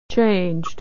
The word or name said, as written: changed